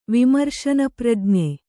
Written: ವಿಮರ್ಶನಪ್ರಜ್ಞೆ

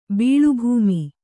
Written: ಬೀಳುಭೂಮಿ

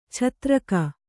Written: ಛತ್ರಕ

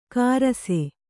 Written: ಕಾರಸೆ

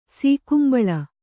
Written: ಸೀಕುಂಬಳ

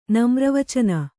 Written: ನಮ್ರವಚನ

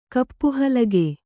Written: ಕಪ್ಪುಹಲಗೆ

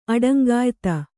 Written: ಅಡಂಗಾಯಿತ

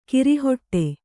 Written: ಗುರಿಹೊಡೆ

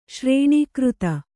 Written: ಶ್ರೇಣೀಕೃತ

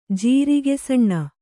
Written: ಜೀರಿಗೆಸಣ್ಣ

